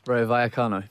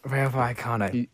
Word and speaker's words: Vallecano: Rayo Vallecano. – Rayo Vallecano. A double L's are pronounced as a Y.